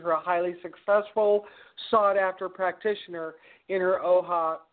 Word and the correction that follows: Ojai: OHA, is that, did I pronounce that properly? – It's Ojai, California Clinic, and so we're really pleased to share her work with you all.